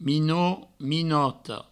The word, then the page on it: minot: minota; minoto